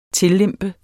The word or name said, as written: TILLEMPET